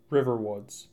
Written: Riverwoods

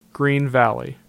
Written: Green Valley

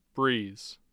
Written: breese

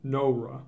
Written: Nora

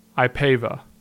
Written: Ipava

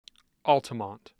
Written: Altamont

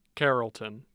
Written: carrollton

